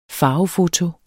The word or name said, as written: farvefoto